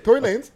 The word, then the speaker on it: Tory Lanez: And don't forget the press of Tory Lane. Tory Lane. I keep saying it, man. – Tory Lane. – Was it the right? I said it right the first time? – Tory Lanez. – Tory Lanez? All right.